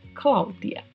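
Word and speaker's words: Claudia: Cláudia também tem uma particularidade de pronúncia, que é o DI. No espanhol, não existe a pronúncia de, e sim di. Portanto, qualquer palavra em espanhol que você encontrar com DI, pronuncie sempre di. Então, o nome é Cláudia.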